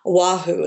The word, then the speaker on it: Oahu: If you think of, if you go to Honolulu, if you think of the island it's on, I think it's Oahu is the way you pronounce it.